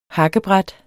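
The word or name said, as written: hakkebræt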